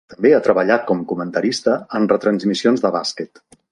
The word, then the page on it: comentarista: Catalan